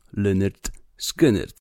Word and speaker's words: Lynyrd Skynyrd: I oni modulowali to imię i nazwisko, mówiąc o nim Leonard Skinnerd, a przy odpowiedniej wymowie wychodziło po prostu Lynyrd Skynyrd.